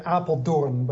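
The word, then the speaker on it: Apeldoorn: Okay, so you go to, it was an Appeldom, is that a Dorf? Isn't that the way you pronounce it, the name in Holland? – Yeah, you say Appeldoorn, but it's okay.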